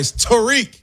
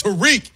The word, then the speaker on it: Tarik: First of all, put some respect and say his name right. Tarik. – Whatever. Tarik Skubal is what I call him. – That's what everybody taught. Tarik. It's Tarik. Sound like he was in the hood.